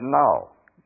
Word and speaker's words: gnaw: Well, now if I spelled a word for you and you tell me how to pronounce this in G-N-A-W, that's null. Did you hear yourself pronounce the G really?